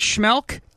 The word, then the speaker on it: Schmeelk: You can get him on Twitter at Schmelk, S-C-H-M-E-E-L-K. John Schmelk joining me right now on CBS Sports Radio. Hi, John. How are you? – What's up, Sean? You got the pronunciation and the spelling right.